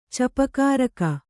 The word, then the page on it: ಚೂಪುಗಾರಿಕೆ: cūpugārike